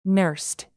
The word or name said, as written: nursed